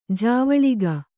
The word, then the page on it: ಜವಳಿಕ: javaḷika